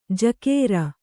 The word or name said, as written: ಝಕಾರ